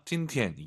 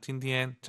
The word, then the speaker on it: 今天: 아아 일성 잘 하셔야 됩니다. 찐티엔 이게 아니라 찐티엔.